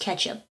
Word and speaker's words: catsup: Matthew Vogel says he spelled it catsup like my mom did, C-A-T-S-U-P. But even then, I've only heard it pronounced ketchup.